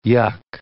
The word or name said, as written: yuk